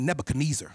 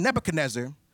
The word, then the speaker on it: Nebuchadnezzar: I used to be like Nebuchadnezzar. That's what I used to say like a church mother. Centuries earlier, King Nebuchadnezzar, he used his army to gather the captives of Judah.